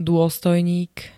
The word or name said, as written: dôstojník